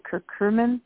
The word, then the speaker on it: curcumin: How do you say it? Curcumin? – Yes.